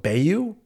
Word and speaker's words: Bayeux: I think it was called Bayou. Ends in an X, you know, like typical French. That's silent X, yeah.